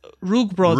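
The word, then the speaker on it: rúgbrauð: It's a hard TH. It's rugbrauch.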